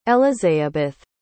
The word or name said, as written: elizeabeth